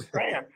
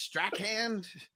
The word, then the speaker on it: Strahan: Strahan? – Oh man, that's a backhand, right? – It's like backhand, but with an ST.